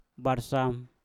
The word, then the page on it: barsam: mpur